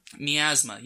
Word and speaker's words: miasma: Miasma, yes.